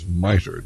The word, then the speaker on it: MITRE: MITRE. Did I pronounce that right, Gary? – Correct.